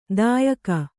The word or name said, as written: ದಾಯಿಗ